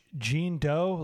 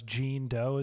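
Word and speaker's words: Jean Deaux: Jean Doe, like it's, but it's spelled D-E-A-U-X, not John Doe. It's Jean Doe is her name.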